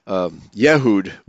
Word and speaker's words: Yehud: It cannot be derived from Judah or Yehud, which is the Hebrew pronunciation. It's the Hebrew word Yehud.